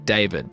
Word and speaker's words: D4VD: I guess you say his name like David, but it's spelled D4VD.